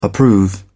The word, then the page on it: approve: British English